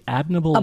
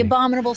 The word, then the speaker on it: Abominable: But you brought some of your wares from am I saying this right? Abominable. Oh my God! – Yes, like the Abominable Snowman.